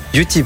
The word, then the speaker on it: Utip: On dit Utip, c'est ça ? – Utip, vous l'avez très bien prononcé.